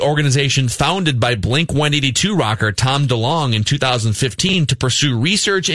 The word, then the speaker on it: DeLonge: Is it Tom DeLonge? Is that how you pronounce it? – Sounds good to me. – Tom DeLonge from Blink-182.